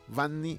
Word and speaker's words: Vanny: Las redes sociales, marcando mi nombre, Vanny Jordan, se escribe V pequeña, o sea, V A-N-N-Y, Vanni, y después Jordan, J-O-R-D-A-N, Vanni Jordan.